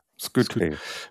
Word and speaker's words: Skytte: Gut, dass du gleich in einem Atemzug Nathan Skytte, wie wird er ausgesprochen? Ist ja Finne, ne? – Skytte.